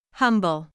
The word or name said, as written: humble